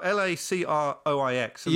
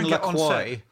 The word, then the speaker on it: LaCroix: But stop saying LaCroix. It's LaCroix because it's named after a city in Wisconsin, not anything to do with France. – Is that right? LaCroix.